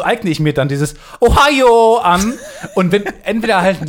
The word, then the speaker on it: Ohio: Aber alle sagen Ohio. Also so, es fließt so aus.